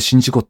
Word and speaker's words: 宍道湖: 宍道湖、お恥ずかしながら読めずにググりました。これで新事故って読むのかっていう、こう、なかなか地名は難しいところですね。